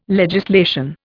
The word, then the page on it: legislation: Indian